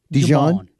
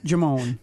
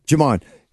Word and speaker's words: Jamon: How do you say his first name? Dijon? – Jamon. Pretend there's no D there. – Yeah. – Okay, Jamon.